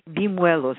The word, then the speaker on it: bimuelos: Bimuelos, it's B-I-M-U-E-L-O-S.